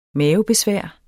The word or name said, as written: mavebesvær